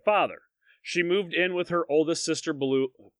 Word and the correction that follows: Beulah: Beulah. Beulah. I'm sorry. Beulah.